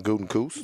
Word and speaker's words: Gutekunst: Gutekunst.